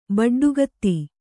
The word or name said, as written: ಬಡ್ಡುಗತ್ತಿ